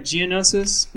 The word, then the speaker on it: Geonosis: Let's jump back to Geonosis. Am I saying that right? Geonosis? – That's right, yeah.